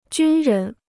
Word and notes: 军人: jūn rén

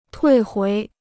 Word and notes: 退回: tuì huí

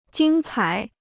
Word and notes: 精彩: jīng cǎi

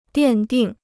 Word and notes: 奠定: diàn dìng

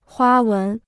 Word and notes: 花纹: huā wén

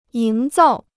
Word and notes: 营造: yíng zào

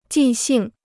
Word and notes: 记性: jì xing

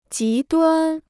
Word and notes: 极端: jí duān

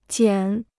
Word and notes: 剪: jiǎn

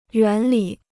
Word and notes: 原理: yuán lǐ

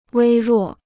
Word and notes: 微弱: wēi ruò

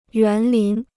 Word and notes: 园林: yuán lín

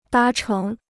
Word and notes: 搭乘: dā chéng

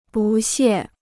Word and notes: 不屑: bù xiè

